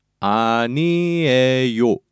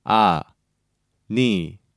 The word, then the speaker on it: 아니에요: One time slowly, it's 아니에요. And now by syllable, 아니에요. 아니에요.